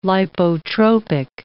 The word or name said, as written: lipotropic